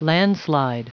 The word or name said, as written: landslide